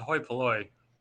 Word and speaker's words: hoi polloi: I just realized what you had said earlier, that it's a hoi polloi, not Hoi polloi. – that you were trying to pronounce before.